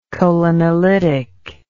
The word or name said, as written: cholinolytic